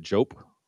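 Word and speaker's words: Joep: So the next one we got is from Jope, and I'm sorry if I mispronounced that. It's J-O-E-P. Would you pronounce it Jope, Hector? – I would hope so.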